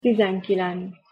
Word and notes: tizenkilenc: Hungarian

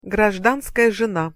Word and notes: grazdanskaja zena: Russian

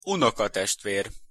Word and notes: unokatestver: Hungarian

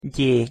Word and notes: gy: Hungarian

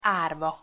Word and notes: arva: Hungarian

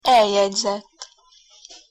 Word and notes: eljegyzett: Hungarian

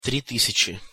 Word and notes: tri tysjaci: Russian

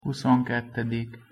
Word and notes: huszonkettedik: Hungarian

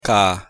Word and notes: k: Hungarian

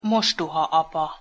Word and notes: mostohaapa: Hungarian

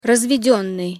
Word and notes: razvedennyj: Russian

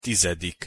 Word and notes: tizedik: Hungarian

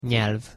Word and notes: nyelv: Hungarian

